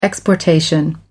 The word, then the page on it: exportation: American English